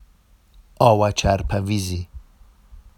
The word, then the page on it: ɑwaʧarpavizi: Vafsi